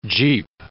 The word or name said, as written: jeep